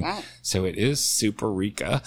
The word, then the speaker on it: Superica: So it's Superica is how it's pronounced.